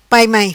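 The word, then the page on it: Pai Mai: patua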